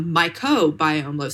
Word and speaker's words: mycobiome: And now we're actually discovering that not only is there a microbiome with the bacteria, but there is a mycobiome. So it's spelled M-Y-C-O, so fungi in our system that has similar functions.